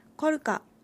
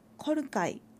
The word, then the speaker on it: コルカ: コルカは浦河町東部や様に道東ではコルカイという発音になることもあります。